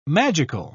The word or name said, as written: magical